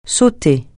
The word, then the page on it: sauter: francais